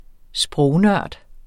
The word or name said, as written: sprognørd